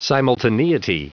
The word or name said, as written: simultaneity